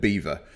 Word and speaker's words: Belvoir: it's spelt Belvoir, B-E-L-V-O-I-R, which sounds quite upmarket, but all the locals call it Beaver.